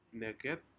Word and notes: neket: Aneityum

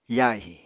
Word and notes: yahi: Apatani

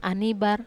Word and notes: anibar: Wamesa

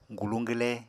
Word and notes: guluñ-gwle: apatani

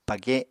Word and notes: page7: Apatani

